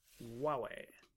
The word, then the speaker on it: Huawei: My understanding from our Chinese colleague who worked with us last year was that you don't pronounce the H. – Nope. – It's Huawei.